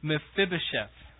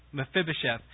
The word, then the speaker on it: Mephibosheth: And that's this strange little story about King David's mercy on a crippled boy, and it's hard to pronounce, named Mephibosheth, right? Mephibosheth.